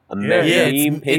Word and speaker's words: meme: Am I saying that right? – Meme page. It's meme-y.